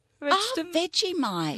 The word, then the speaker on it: Vegemite: Wie spricht man den richtig aus? – Vegetable. – Da haben wir schon den ersten Versuch. Wie heißt der? – Vegetable. – Vegemite.